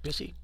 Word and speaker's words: Bisi: Did I pronounce that right? Busy? – B-I-S-I. Let's say you did.